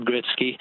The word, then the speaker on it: Gretzky: Wayne Gretzky has always been pronounced Wayne Gretzky,